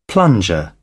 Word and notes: plunger: British English

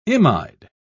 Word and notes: imide: British English

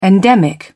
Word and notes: endemic: British English

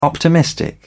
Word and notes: optimistic: British English

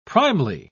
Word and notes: primely: British English

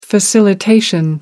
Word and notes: facilitation: American English

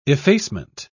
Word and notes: effacement: British English

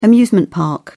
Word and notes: amusement park: British English

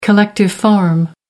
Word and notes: collective farm: American English

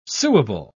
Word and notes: suable: British English

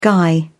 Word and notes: guy: British English

